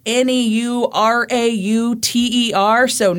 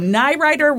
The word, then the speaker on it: Neurauter: And uh, let me just tell you, this name is spelled N E U R A U T E R. So Nyrider was. N- not mm-hmm. even close to how I was pronouncing it.